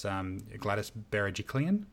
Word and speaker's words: Gladys Berejiklian: I think it's Gladys Berejiklian.